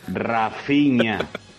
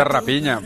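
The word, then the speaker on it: Raphinha: PH es una F, hablado. A ver si aprendemos un poco de portugués. Rafinha, si es brasileño, el nombre es en portugués. PH se pronuncia F, señores. Rafinha.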